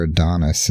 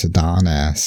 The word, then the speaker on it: Adonis: It's no longer Adonis. It's Adonis.